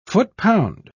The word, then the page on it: foot-pound: British English